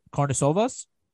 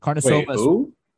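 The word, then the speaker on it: Karnišovas: It's Karnisovas. – That sounds close, but honestly, I have no idea. – I think that's how you pronounce it. Karnisovas, the GM of the Chicago Bulls right now.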